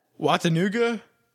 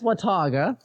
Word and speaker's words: Watauga: Watanuga? – You see it there? – Yeah, Watanuga. – That's Watauga.